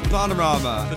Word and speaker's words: Bananarama: Bananarama.